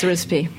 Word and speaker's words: Arispe: Yes, it took me a good 6 months to pronounce Arispe, but it's Arispe.